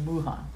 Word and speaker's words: Wuhan: Juan, I think. W-U-H-A-N. It's the place where the coronavirus started. – Wuhan. – Wuhan. If that's how you say it? – Yeah. – Wuhan. Okay, Wuhan.